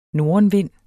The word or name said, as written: nordenvind